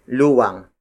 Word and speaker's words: luwang: When measuring an area like a piece of farmland, our ancestors used luwang. Luwang. Luwang. Luwang is spelled as L-U-W-A-N-G.